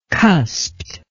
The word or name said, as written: cusped